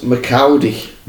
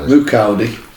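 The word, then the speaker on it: Moukoudi: Yeah, I'll probably get his name wrong. Harold McCowdy. – Mukowdy.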